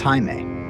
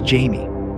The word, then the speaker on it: Jaime: He asked his teachers and later his bosses to say his name not as Jaime, the typical Spanish pronunciation, but as Jamie.